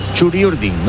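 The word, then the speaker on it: Txuri Urdin: Esperamos a que por la Bocana aparezcan los futbolistas Churi Urdines. No, Churi Urdin. No se dice Churi Urdines, se dice Churi Urdin.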